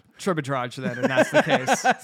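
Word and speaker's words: Tribidrag: Ends in a G. Probably a hard G. So kind of like, you know, we had Dragic on our team. You pronounce that G real hard. So Tribodrag.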